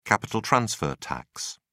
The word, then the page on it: capital-transfer tax: British English